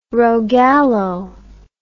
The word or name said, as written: rogallo